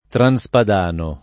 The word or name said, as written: transpadano